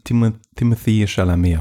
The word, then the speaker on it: Timothée Chalamet: Wie spricht man Timothée Chalamet aus? Ist das richtig? – Also, wenn man wirklich Französisch das ausspricht, ist das Timothée Chalamet.